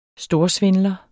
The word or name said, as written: storsvindler